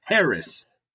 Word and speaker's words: Haracz: Harris, and it's said just like it's spelled, H-A-R-A-C-Z, Harris.